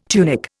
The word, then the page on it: tunic: Indian